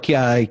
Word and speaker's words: Keah: Is Kia Cunningham here? – Oh. – How do you spell that? – Son? – K-E-A-H. – It sounds like Kia Garam, but it's spelled differently. – I do not see that name. – K-E-A-H.